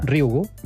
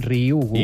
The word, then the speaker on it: Ryugu: Ryugu. Ryugu. És molt fàcil, com una I, Ryugu.